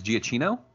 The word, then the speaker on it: Giacchino: And then I might have mispronounced his name. You said is Giacchino? – I believe that's how it's pronounced.